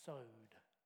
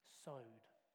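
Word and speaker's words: sowed (סוד): However, the Hebrew word is sowed. Sowed. Okay? It is spelt in English K, sorry, C O W D. Okay? But it's pronounced sowed.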